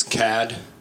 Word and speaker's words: Cad: My name is Cad. It's spelled like Chad, but with a hard C sound, because I do things differently.